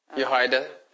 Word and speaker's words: Jehoiada: Jehoiada.